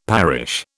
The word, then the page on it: parish: British English